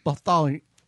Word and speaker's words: Bartholomew: We would recommend the Mel Bartholomew. I think that's how you say it. – Mel Bartholomew. – There you go.